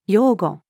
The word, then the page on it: 用語: female